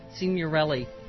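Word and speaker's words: Signorile: Michelangelo Signorini, our buddy. – Signorelli. – Signorelli. Sorry. You know, Urza Klein is the one that told me to mispronounce that.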